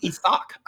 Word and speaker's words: Isaac: It's actually, it's pronounced Isak.